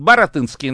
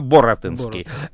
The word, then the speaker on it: Баратынский: Кстати, иногда пишут Баратынский, иногда Боратынский. Это именно из-за разной транскрипции с польского языка. – А, вот, понятно. Ну, то есть, в принципе, и так, и так. – Да, да. Вообще традиция пишется Баратынский, и я так ее и придерживаю. Но если просто кто-то увидит Боратынский, имейте в виду, что это тот же самый человек.